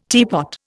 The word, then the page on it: teapot: Indian